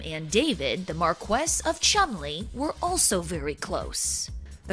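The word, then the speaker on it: Cholmondeley: That's Marchioness of Chumley, which is spelled Cholomandilly. Cholomandilly. It's pretty much the same way it's pronounced Worcester, but spelled Worcestershire. Worcestershire.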